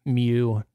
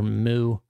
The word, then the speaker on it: mu: It is mu, not mu or moo.